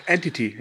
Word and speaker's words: Entity: Oder wird es Entity ausgesprochen, wo der Geist immer die Frau vergewaltigt und der nachstellt? – Ach, Entity, ja. – Ja, ja, dann heißt das so.